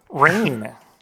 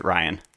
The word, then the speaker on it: Rian: Rain. – No, it's spelled with an I, but it's pronounced Ryan.